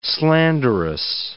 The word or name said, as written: slanderous